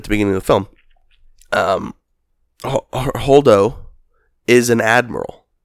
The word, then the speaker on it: Holdo: Holdo. – Holdo. Hold up. – H-O-L-D-O.